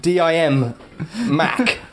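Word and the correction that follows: Dim Mak: Is that right? – No, it's actually pronounced a dim mark.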